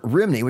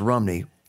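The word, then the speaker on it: Rhymney: But oddly enough, Bells of Rimini, which is actually pronounced Rumney, forgive me, my Welsh friends, there's no such town as Rimini, it's Rumney.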